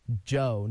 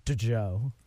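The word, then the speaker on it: Djo: He texted me this morning while Faith was on the air and was like, you need to tell your DJs that it's pronounced Joe, not D-Jo.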